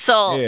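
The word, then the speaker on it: Soule: Taylor's, how you say her name? Soul? Is it Soleil? – So you had Taylor Soul too. – It's Soul.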